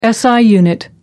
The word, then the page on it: si unit: American English